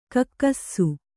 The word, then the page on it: ಕಕ್ಕಸ್ಸು: Kannada